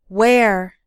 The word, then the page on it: wear: American English